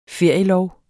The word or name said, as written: ferielov